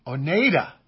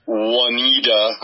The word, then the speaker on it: Oneida: Rimaji, Katakana, Japan, they would say Oneida, because the EI has an A sound. Yeah, long A. – Yeah, sound. – Or in the UK, Oneida. They tend to say Oneida.